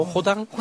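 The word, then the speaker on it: Rodin: 그냥 파리는 R은 흐 발음을 하기 때문에 파리도 파희라고 하고 우리가 말하는 로댕도 호당이라고 하는 거예요. – 아 그래요? 호당? – 호당.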